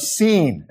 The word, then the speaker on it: Sin: And that's how you should pronounce that, lest you confuse it with the wilderness of sin. It's true that Israel sins in the wilderness, but this is the wilderness surrounding Sinai, and that's why it is spelled S-I-N.